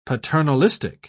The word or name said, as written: paternalistic